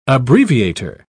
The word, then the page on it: abbreviator: British English